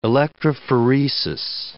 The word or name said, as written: electrophoresis